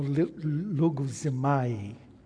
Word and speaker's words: logizomai: Or logizomai, better pronounced.